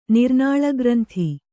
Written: ನಿರ್ನಾಳಗ್ರಂಥಿ

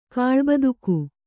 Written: ಕಾಳ್ಬದುಕು

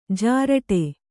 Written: ಜರಟಿ

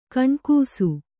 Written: ಕೆಣಕಿಸು

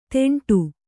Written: ತೆಂಟು

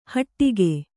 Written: ಹಟ್ಟಿಗೆ

